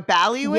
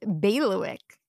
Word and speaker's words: bailiwick: Ballywick is not the pronunciation. Bailiwick is the pronunciation. So it's a long A, like bay, and the stress is on the bay. And instead of leewick, it's lewick. Bailiwick.